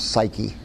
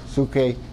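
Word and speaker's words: Suke (ψυχή): The ancient word for life that's translated soul, I'll just touch on this because I made reference, is usually pronounced by our English tongue as psyche. Psyche. I wish to the Lord we'd never translate it. Suke, psyche. Usually pronounced something like that.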